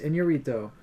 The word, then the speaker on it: Iñárritu: This is how you pronounce it. – Iñárritu. – Yeah. – Okay, there you go. So Alejandro González Iñárritu.